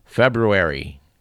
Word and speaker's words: February: The date in question is the 20th of February. That's with two R's, both pronounced.